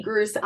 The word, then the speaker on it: Gruss: So I'm Heidi Gruss on all platforms, and my last name, it rhymes with Seuss, but it's spelled differently. It's G-R-U-S-S. So there's no E in there.